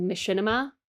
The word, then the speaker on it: Machinima: which is called Machinima. If I'm saying that rightly, yeah, rightly, rightly.